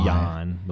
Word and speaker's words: Bojan: I say it's Bojan. – He had the quietest 30-point night I've ever seen in my life. – Boyan, the way they pronounced it last night.